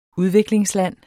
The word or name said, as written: udviklingsland